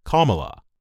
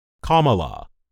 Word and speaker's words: Kamala: The Associated Press has reported Harris's first name is pronounced Kamala, or, as she explains in her biography, Kamala, like the punctuation mark.